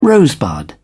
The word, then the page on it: rosebud: British English